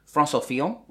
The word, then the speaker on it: François Fillon: So, François Fillon, which I think is how it's pronounced. – Yeah.